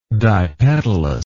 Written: dipetalous

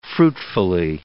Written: fruitfully

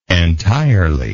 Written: entirely

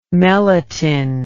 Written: meletin

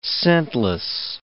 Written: scentless

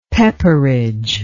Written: pepperidge

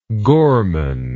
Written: gormand

